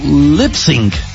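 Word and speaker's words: Lipps Inc: Se escribe L-I-P-P-S, aparte I-N-C, Lips Inc. Pero se pronuncia como Lipsync.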